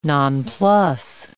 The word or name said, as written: nonplus